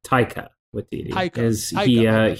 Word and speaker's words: Taika Waititi: one of Taika Waititi, is that how you pronounce it? – Yeah, Taika Waititi.